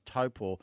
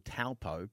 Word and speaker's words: Taupō: We're going to be chatting about our third episode, which is coming up, which is Taupō. It's pronounced by many people Taupō, but it's actually Taupō. That's the Māori way of speaking it.